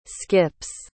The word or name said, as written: skips